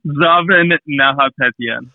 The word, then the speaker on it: Zaven Nahapetyan: Zaven Nahapetyan.